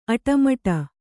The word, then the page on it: ಅಟಮಟ: aṭamaṭa